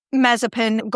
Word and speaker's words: Mazepin: And they've got links back to Mazepan. Did I say that right? – Mazepin. – Whose father has links to Vladimir Putin. – He's an oligarch. – Mm. Yeah, which is why Mazepin got dropped.